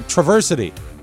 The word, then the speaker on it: Traverse City: There's a pronouncer there. Traversity. – Oh, Traversity. – Because they put the S. That's how they say it there, he said. He was very specific.